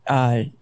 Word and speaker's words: kombucha: all kinds of great kombucha.